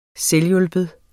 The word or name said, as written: selvhjulpen